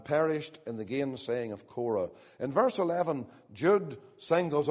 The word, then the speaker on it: Korah: Spelt here C-U-R-E. It's pronounced Korah.